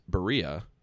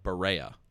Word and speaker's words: Berea: It is on Lou Groza Boulevard in correctly pronounced Berea. But if you ever watch SportsCenter or anything on ESPN, they always botch it and call it Berea.